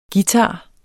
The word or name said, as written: guitar